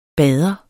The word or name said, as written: bader